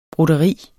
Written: broderi

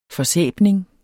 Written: forsæbning

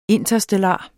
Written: interstellar

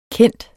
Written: kendt

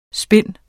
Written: spin

